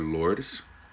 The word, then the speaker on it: Lourdes: Is it Dr. Lourdes? – Yes, Lourdes. That's fine. – Oh, I did say it. Did I say it right, though? – Yes, you said it. – Perfect. – You were correct.